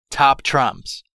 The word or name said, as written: top trumps